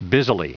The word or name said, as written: busily